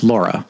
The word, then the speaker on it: Lore: Did you see a 2012 film called, it was spelled Lore, but it's German, so it's pronounced Laura.